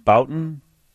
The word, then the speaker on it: Bouton: Jim Bouton? – I believe that's right, yeah.